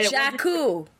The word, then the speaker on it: Jakku: I kept calling it Djibouti. – And Jakku. Jakku.